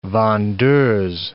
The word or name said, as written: vendeuse